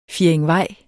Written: fjerdingvej

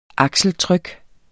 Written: akseltryk

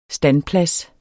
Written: standplads

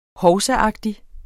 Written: hovsaagtig

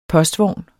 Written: POSTVOGNE

